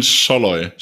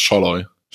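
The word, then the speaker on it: Szalai: Also und den, der Salay ist, spricht man Salay und den, der Salay geschrieben wird, spricht man Scholloy. Also ein SZ wird zu einem scharfen S im Ungarischen mhm. und ein S wird, spricht man wie ein SCH und durch das Doppel-L werden diese A's scheinbar zu O's. Also man spricht es Scholloy.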